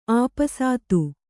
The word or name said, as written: ಆಪಸಾತು